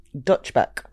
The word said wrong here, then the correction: Dutch Pot: Dutch pot. Like pot. Netherlands. – I'm going to get you to spell it for the viewers. – D-U-T-C-H-P-O-T.